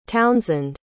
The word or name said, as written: townsend